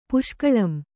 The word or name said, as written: ಪುಷ್ಕಳಂ